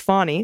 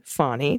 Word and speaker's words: Fanny: In this shot, are our cat Fanny, pronounced Fawny, okay, Fawny, just like Fawny Willis, and our 2 Australian shepherds.